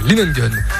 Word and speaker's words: Lingen: Pouilly-en-Ossois est jumelé depuis 1988 avec la ville de, j'espère bien le prononcer, Lingen. C'est ça, je prononce bien, monsieur l'adjoint? Ok, Lingen.